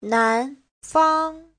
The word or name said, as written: nan2fang1